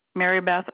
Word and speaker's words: Meribeth: Mary Beth Ogrentz. Mary Beth is spelled M-E-R-I-B-E-T-H, and Ogrentz is O-G, like George, R-I-N, like Nancy, C, like cat.